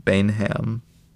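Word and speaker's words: Baynham: Written by Peter Baina. Bainham. – Yeah, Bainham. If I can read. – Bainham. – Bainham. – It's probably the more correct way to say it.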